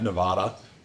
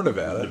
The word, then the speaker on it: Nevada: So Joel obviously played tackle at Nevada. Or Nevada, depending on how you say it.